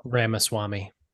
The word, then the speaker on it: Ramaswamy: That how that name is pronounced? Ramaswamy? – Yeah. Ramaswamy.